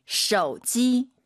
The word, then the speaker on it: 手机: 휴대폰 선생님 저 발음 좀 교정해 주세요. 쇼지 쇼지 아니죠? – 手机. – 오유는 어예요.